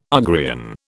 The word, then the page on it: Ugrian: British English